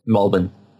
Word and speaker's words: Melbourne: Melbourne. No, you don't pronounce the R.